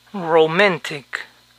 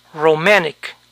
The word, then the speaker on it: romantic: Romántico se pronuncia romantic, romantic. Como pueden ver, la N está en la sílaba acentuada, roman, y la T en la siguiente sílaba, sin acentuación, tech. Por lo tanto, esta palabra puede escucharse romantic, romantic.